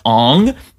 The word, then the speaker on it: Aang: For example, Aang in this is pronounced Ong.